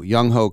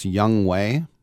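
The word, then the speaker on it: Younghoe: It's pronounced Young Way. Young Way Koo.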